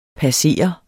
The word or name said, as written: passer